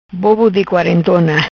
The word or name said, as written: Bobo Di Quarentona